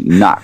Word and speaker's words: Nick: Like, you guys call me Nick, and I'm not sure that I've ever pronounced my name Nick. It's more likely to be Nuck, isn't it? – It was just the one thing standout.